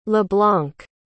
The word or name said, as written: leblanc